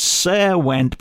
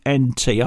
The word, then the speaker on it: Caerwent: It looks like it's Sair Went, but it's probably Care as in Caerwent. It's spelled Caerwent. I'm going for Caerwent in South Wales.